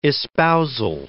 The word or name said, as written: espousal